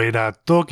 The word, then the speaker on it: Erato: El disco de este Kairos Collective se titula Erato, o Erato, quizá habría que decir, si nos atenemos a la pronunciación original, la musa en la mitología griega de la poesía.